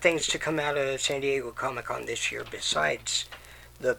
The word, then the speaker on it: San Diego: Comic-Con was this week in the San Diegers. – Yeah. Super cool. – It's pronounced San Diego. – San Diego.